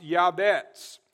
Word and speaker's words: Jabez: Jabez, the actual name, is a Hebrew word that's pronounced Yabetz.